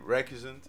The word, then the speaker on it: Recusant: che si intitola Recusant. È giusta la pronuncia? – Perché... Sì, direi di sì, che... sì, Recusant.